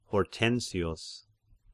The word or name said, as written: Hortensius